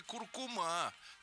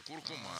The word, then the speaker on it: куркума: В России определились с правильным ударением в слове «куркума». Это привет нашей Юрьевне. Главный филолог страны из Виноградовского, не товарищ Пахомов, заявил, нет, на «а» в конце. Куркума.